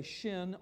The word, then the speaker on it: shin: and it'll say shin or and or sin, S-I-N, which is another Hebrew way of pronouncing that letter.